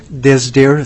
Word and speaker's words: Disdier: My name is Paul Disdier. It's spelled D-I-S-D-I-E-R, and I am the general manager of the Fireweed Factory, LLC.